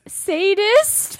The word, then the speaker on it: sadist: Wow, there's your masochistic sedatist. I think that's a word. Did I say it right? – No, you did not. – What did I say wrong? – You said sedatist. It's sadist.